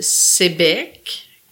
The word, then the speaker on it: Sayabec: Dans la vallée de la Matapédia, dans le, dans la vallée, euh, une, petite, euh, un petit village qui s'appelle Sébec, qui s'écrit Sayabec qui, est, ah.